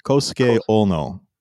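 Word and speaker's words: Kousuke Oono: Nope. Double O is long O. Actually, double O is long O, and O-U in his first name is also long O. They've chosen two different romanizations. Kosuke Uno.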